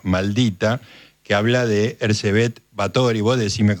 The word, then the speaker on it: Erzsébet Báthory: y desde ya decime cómo se pronuncia el nombre. – Erzsébet Báthory. – Ah, lo pronunciaste muy bien.